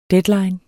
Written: deadline

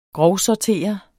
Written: grovsortere

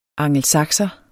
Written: angelsakser

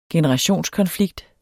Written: generationskonflikt

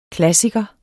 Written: klassiker

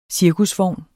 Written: cirkusvogn

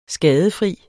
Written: skadefri

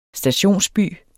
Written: STATIONSBYER

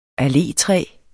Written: allétræ

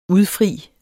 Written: udfri

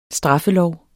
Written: straffelov

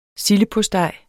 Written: sildepostej

